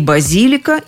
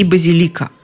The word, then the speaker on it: базилика: Заметьте, оба варианта – варианты нормы. Результат – практически все словари разрешают в этом слове двойное ударение – и базилика, и базилика – это и справочники Розенталя, и современный словарь иностранных слов, и словарь ударений Резниченко, и, что еще важно, авторитетный орфоэпический словарь под редакцией Аванесова.